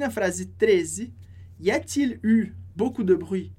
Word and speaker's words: eu: Lembrando que esse u, e, u, a gente pronuncia só com o u, que é o verbo avoir no passado.